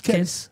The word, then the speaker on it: Kells: Comment on prononce Kells. Tout simplement, le livre de Kells.